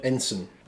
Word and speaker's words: ensign: And good pronunciation of the word ensign. – I didn't realise there was a second way of pronouncing that, so there we go. – Well, some people say ensign, but that's wrong.